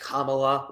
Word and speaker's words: Kamala: It's going to be Kamala, or excuse me, I think her name is pronounced Kamala, like I give a shit.